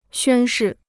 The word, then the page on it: 宣誓: xuān shì